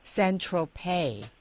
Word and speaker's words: Saint-Tropez: Saint-Tropez. – Right. – 是那个 Z doesn't. – It is not pronounced Saint-Tropez. Yeah. yeah. – Saint-Tropez. – Some people say Saint-Tropez, but I think the French say Saint-Tropez. Yeah.